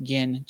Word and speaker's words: Ginn: It's probably pronounced Gin, G-I-N-N.